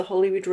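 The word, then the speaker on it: Holywood: We lived in an area called the Hollywood Road, as in spelt Holy, not Holly, but pronounced Holly.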